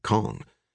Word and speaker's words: Kang: She spells it K-A-N-G, but she pronounces it Kong.